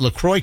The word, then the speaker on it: LaCroix: That's like Cajun Fester's Alley with that LaCroix kind of thing. – That's how I knew how to pronounce it. – Yeah, that's all like Cajun sound, LaCroix with a C-R-O-I-X, man.